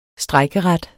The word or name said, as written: strejkeret